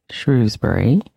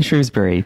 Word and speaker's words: Shrewsbury: I should also say I am pronouncing this town as Shrewsbury, although it is also pronounced by locals as Shrewsbury.